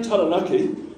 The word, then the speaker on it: Taranaki: So you might say Taranaki, because you know that's how it's pronounced,